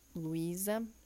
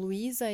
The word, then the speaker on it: Luísa: Eu sou Luísa, e quem quiser entrar em contato é só passar lá no meu Instagram. Meu nome se escreve com S, então Luísa M Holística.